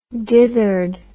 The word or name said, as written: dithered